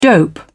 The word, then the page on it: dope: British English